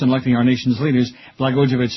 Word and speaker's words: Blagojevich: A bill signed into law Monday by Governor Rod Blagojevich, how do you say his name? Blagojevich, made Illinois the third state after Maryland and New Jersey ready to bypass the Electoral College in November.